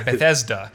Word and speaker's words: Bethesda: They've had some expansions and add-ons and things like that, but Fallout 4, which is developed by Bethesda. – I'm assuming that's how you pronounce it. It's like a very biblical sounding name, actually. – Yeah, Bethesda.